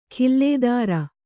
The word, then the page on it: ಖಿಲ್ಲೇದಾರ: khillēdara